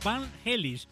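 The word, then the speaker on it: Vangelis: ¿Qué recuerdos me trae Carros de Fuego de mi admirado Van Gelis? – ¡Oh, qué bueno! Van Gelis. – Es Van Helis, sí, como sí, holandés, ¿no? Van Helis.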